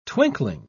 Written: twinkling

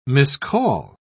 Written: miscall

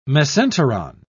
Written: mesenteron

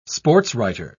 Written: sportswriter

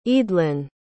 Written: eadlin

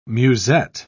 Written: musette

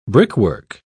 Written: brickwork

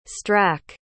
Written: strack

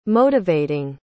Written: motivating

